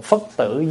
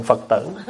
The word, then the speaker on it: phất tử: Cho đọc cái câu đó phải đọc là cô hồn phất tử giáng lâm lai, chứ không phải cô hồn Phật tử.